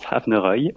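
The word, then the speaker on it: Aibhneacha: Alors, ça s'appelle Ebneasha Rivers. Non, je l'ai bien dit, c'est bien ça? – Presque. C'est du gaélique, donc ça se prononce Havneroy. Les lettres n'ont rien à voir avec la prononciation, mais on peut garder Rivers, qui est la traduction anglaise, Havneroy signifiant rivière au pluriel en gaélique.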